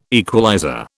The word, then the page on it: equalizer: British English